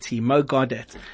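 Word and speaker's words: Mo Gawdat: Mo Godet, yes. Just tell us how you spell it again. – So it's M-O-G-A-W-D-A-T, Mo Godet.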